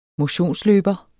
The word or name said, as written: motionsløber